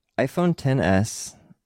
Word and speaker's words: iPhone XS: I'm looking at the site here and the iPhone 10s which is how it's pronounced, on iPhone XS, which is oh, kind of a we, funny thing.